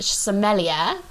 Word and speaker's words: sommelier: So today's book is The Summer Job, which is about Heather, who's a very well-established sommelier. Have I said that right? – Yeah. – Sommelier.